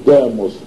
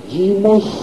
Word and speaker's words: димос: Это стало произноситься как «ито», поэтому не «демос», а «димос», а группа народная, группа граждан «дима», глава же ее, предводитель «димарх».